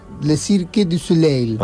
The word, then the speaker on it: Le Cirque du Soleil: Le Cirque du Soleil.